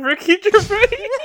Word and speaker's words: Gervais: Ricky Gervais. – There we go. There. You got it. – I knew there was an S in there that some people pronounce and some people don't.